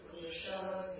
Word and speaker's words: Pusha: Swastina Pusha Vishwavedaha. – That pu, the Pusha is long u.